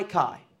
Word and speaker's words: kai: It is kai, probably butchering the pronunciation. You can spell it in English, K-A-I, but it's pronounced kai.